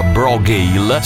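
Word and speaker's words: Brògeal: Si chiamano Brogale, si scrive Bro con la O accentata Gael, ma si pronuncia Brogale.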